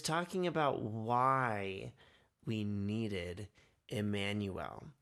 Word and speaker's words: Immanuel: It's Immanuel, or Immanuel is how you pronounce that.